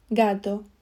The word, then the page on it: gato: Spain Spanish